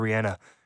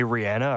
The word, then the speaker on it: Rihanna: I have to call her Rihanna. Because my friend's from Barbados, and that's how you actually say her name.